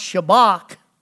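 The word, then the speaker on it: shabach: That word shout is interesting in the Hebrew. It's pronounced shabak.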